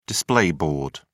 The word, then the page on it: display board: British English